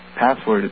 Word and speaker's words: passwd: So I printed out the file, which is called Password. It's spelled P-A-S-S-W-D.